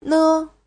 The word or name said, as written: ne5